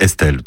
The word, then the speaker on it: ESTL: Alors faites pas comme moi, hein, si vous voulez pas passer pour un idiot, l'appelez pas E-S-T-L, c'est Estelle, tout simplement. Mais ça s'écrit E-S-T-L.